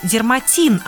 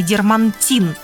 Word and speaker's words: дерматин: Итак, запомните – дерматин, а не дермантин.